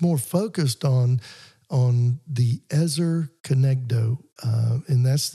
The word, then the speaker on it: Ezer Kenegdo: But it's Ezer, E-Z-E-R, and it's Kenegdo, K-E-N-E-G-D-O. – Got it. – Ezer Kenegdo.